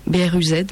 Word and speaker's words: Bruz: Et du coup, il a pour but de devenir aussi un festival métropolitain en gardant toujours comme centre Bru, BRUZ, mais ça se prononce Bru, désolé.